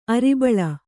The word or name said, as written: ಅರಿಬಳ